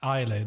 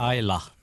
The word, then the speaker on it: Islay: Är det en Islay då eller? Eh, Islay ja. Islay Eller Ayla som det då heter. – Ayla. Alltså det uttalas Ayla. aldrig som, det, som man tror.